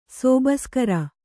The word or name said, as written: ಸೋಬಸ್ಕರ